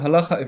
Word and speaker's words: halacha: Judaism, halacha, evolves. Halacha means, sounds like the word halicha, to walk in Hebrew.